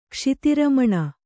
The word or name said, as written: ಕ್ಷಿತಿರಮಣ